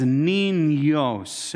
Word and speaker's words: niños: It's the end with that little squiggly line above it, and that tells you that that N is pronounced with a nya. It has a Y on it, and that's why it says niños, and it's the two types of N.